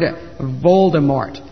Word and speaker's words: Voldemort: Harry was a survivor in a battle involving his parents and an evil wizard named Lord Voldemort. Now, Voldemort is spelled V-O-L-D-E-M-O-R-T. It's actually a French word, so you don't pronounce the T, but most people do.